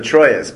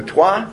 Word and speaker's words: Troyes: He was born in, now, an American would pronounce that the Troyes, but I know that that's not, the Trois, is that right?